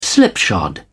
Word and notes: slipshod: British English

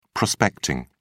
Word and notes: prospecting: British English

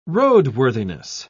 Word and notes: roadworthiness: British English

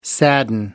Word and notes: sadden: American English

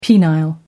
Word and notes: penile: American English

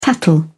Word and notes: tattle: British English

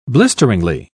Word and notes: blisteringly: British English

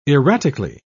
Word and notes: erratically: British English

